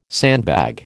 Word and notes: sandbag: American English

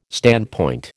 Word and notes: standpoint: American English

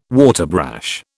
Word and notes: waterbrash: British English

